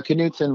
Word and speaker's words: Knudson: Uh, Knudson, but it's great.